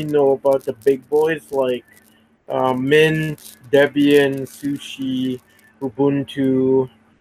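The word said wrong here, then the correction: SUSE: First of all, can we just correct your pronunciation of Sushi, man? This is not fish, it's Sousa. Open Sousa.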